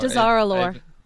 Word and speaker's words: Dazar'alore: I want to say Darzalore. Is that how you pronounce that? – Darzalore. I don't know. – Dazar'alore. – Dazar'alore. Thank you.